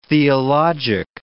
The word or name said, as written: theologic